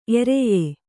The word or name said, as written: ಎರೆಯ